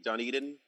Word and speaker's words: Dunedin: I like to go done, like, are you done eating? Dunedin, you know? You done eating? – You're done eating, exactly. – They hate it when you say Dunedin.